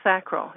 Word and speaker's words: sacral: Now, it's cranial sacral. Is that correct? Am I saying it right? – Sacral, cranial sacral. – Sacral.